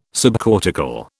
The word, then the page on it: subcortical: British English